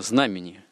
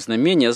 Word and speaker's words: знамение: Как тот тоже из лингвистов-филологов как-то заметил, что это ужасное знамение. Вот, потому что правильное ударение на знамени. – Ну, конечно. – Не знамение, а знамени. – Да, и корень-то знамя.